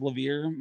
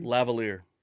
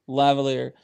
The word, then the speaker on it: lavalier: I bought, is it pronounced Lavier microphone? – Lavalier. – Lavalier.